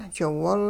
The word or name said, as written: jiwol